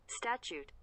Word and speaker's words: statute: So a land mile or, I looked this up how to pronounce. Statute. – Nice. – Statute. Okay, statute miles. It's S-T-A-T-U-T-E. – Yeah, statute. – Statute. – Like a statue.